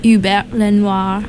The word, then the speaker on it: Hubert Lenoir: Summertime, High Time, More, Whatever Comes to Mind, and then Hubert Lenoir. – The correct French pronunciation.